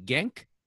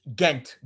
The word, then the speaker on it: Ghent: And is the city, how do you pronounce the city? Genk? – Ghent. G-H-E-N-T.